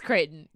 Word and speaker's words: Creighton: Corrections Corner. We pronounce Creighton's name incorrectly. – Creighton. – Creighton. – It's Creighton. And Lexi was, I think, was, I don't think she was like 100% correct, but she was like definitely 90% correct. And we Googled it, and that's how it's pronounced in the British way.